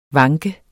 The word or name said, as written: vanke